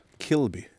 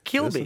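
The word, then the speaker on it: Quilloughby: men Kilo, Kilogby, Kilogby, hur ska man säga det? – Kilby. – Kilby, yes. förlåt.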